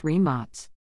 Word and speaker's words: Rimac: The Nevera is a next-generation hypercar designed and produced by Croatian automotive manufacturer Rimac Automobili, pronounced Rimac.